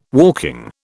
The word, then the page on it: walking: British English